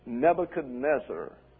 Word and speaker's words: Nebuchadnezzar: Nebuchadnezzar.